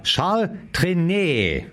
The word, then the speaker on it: Charles Trenet: Na gut, vorher sagt mir gerade ein Hörer, na, in Französisch warst du gut, nur nicht in der Sprache. Ja, Charles Trenet. So, jetzt haben wir es aber.